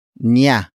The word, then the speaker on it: niya: The next word is niya. Niya. Niya. Niya is spelled as N-I-Y-A.